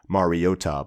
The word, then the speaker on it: Mariota: I know there are very vocal people about the pronunciation of his name, which is supposed to be Mariota, but whatever.